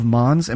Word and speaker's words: Mons: the Angel of Mons.